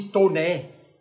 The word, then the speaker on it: Taunay: Segunda coisa, também uh, o outro historiador é o Visconde de Toné. Escreve Taunay. Eu nem sei se pronuncia Toné, mas eu acho que sim, porque o nome dele é meio afrancesado.